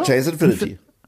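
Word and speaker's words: Chase Infiniti: Ja, Chase Infinity.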